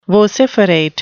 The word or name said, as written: vociferate